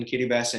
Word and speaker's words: Kiribati: Now it is pronounced Kiribati. The T-I, or that T in the T-I is actually pronounced like an S.